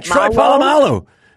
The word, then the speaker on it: Troy Polamalu: My favorite hair has been, if I'm saying his name right, Troy Polamalu. – Troy Polamalu. Man, you nailed that.